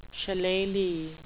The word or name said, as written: shillelagh